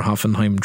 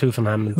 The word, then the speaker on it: Hoffenheim: like your Hoffenheim trip last year. – It's pronounced Hoffenheim.